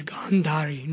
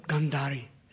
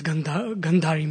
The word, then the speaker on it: Gandhari: Actually, it's Gandhari, not Gandhari. Because Gandhari means something else. It's a long A, Gandhari. Gandh means smell, you know, unfavorable smell, unfavorable aroma, like that. So it's Gandhari.